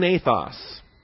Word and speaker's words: ethos: Well, sun ethos. Ethos. Right here. E-T-H-O-S. That's the long E. That's the eta. E-T-H-O-S. Ethos. Now, when you write out ethos in English letters, E-T-H-O-S, what does that look like?